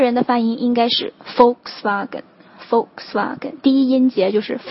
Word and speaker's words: Volkswagen: 大众，这个也是在中国非常受认可的一个牌子啊，下面有很多很多系列的车，那它的正确发音呢，实际上是 Volkswagen。其实我也一直念 Volkswagen，因为英国人也是这么念的 Volkswagen，但是它的正确发音实际上应该是 Volkswagen。